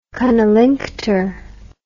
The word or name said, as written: cunnilinctor